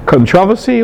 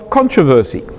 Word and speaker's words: controversy: That's because, so my dictionary says, there are two possible pronunciations of controversy: controversy or controversy. The former pronunciation is more traditional, old man, but the latter is more now widespread in the British English speaking world, which is the whole world.